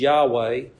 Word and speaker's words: Yahweh: And that word Lord is the capitalization that is standard in our English translations, capital L-O-R-D, which tells us that behind it comes the holy covenant name of God. Usually these days it's pronounced as Yahweh, though in earlier times it was often pronounced as Jehovah.